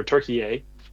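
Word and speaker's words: Türkiye: Turkey or Turkey. As the proper pronunciation.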